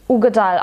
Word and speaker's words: Uigeadail: Ähm, Ugedal als Name, Uigedeal geschrieben, glaube ich. – Ugedal ausgesprochen, ähm, kommt vom Loch Ugedal der Name.